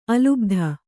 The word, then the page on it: ಅಲ್ ಬತ್: Kannada